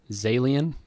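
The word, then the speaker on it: Zaillian: Screenplay written by Steven Zalian. Rhymes with alien.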